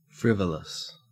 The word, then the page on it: frivolous: Australian English